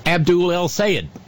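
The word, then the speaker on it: Abdul El-Sayed: He endorsed Abdul El Sayed.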